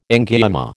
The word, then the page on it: angioma: American English